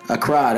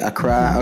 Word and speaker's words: Accra: Accra. – That's how you say it. Accra.